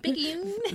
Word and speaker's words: Picayune: The newspaper, the Daily Picayune, reported on that name. Sorry. Picayune. – The Daily Picayune. I'm assuming that's how you pronounce it. – It is.